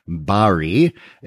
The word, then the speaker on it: Barry: Barry, that's B-A-R-R-Y, not B-E. I'm not a fruit. Well, I'm a little fruity, but it's actually Barry. My mother-in-law says I pronounce my own name wrong, Barry. She said, no, your name is Barry. She's from New Zealand, so she speaks proper English.